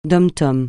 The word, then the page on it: DOM-TOM: francais